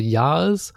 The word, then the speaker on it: Yağız: Also Yağız, dieses G, das hat so einen Akzent drauf und das heißt, dass es ein stummes G ist. Genauso wie bei Erdoğmuş.